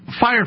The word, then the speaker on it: Fyre: Fire Festival. It's spelled FYRE.